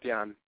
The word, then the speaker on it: Jann: It's Jann Klose. Hi. – Hi, hi. So, Jann, tell us a little bit about yourself. – Just, my name is pronounced Jann.